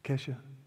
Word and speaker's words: Kesha: Who in here is it Kesha? Did I say it right? Kesha, okay.